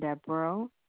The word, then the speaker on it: Debrow: L-A-V-E-T-T-E. – And your last name? – Is Johnson Deborah. – Johnson hyphen Deborah. D-E-B. – Yes, R-O-W. – D-E-B. R-O-W.